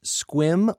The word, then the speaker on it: Sequim: Sequim, Washington is pronounced Squim, Washington. And apparently there's a town in Louisiana along the border with Texas called Iowa, not Iowa.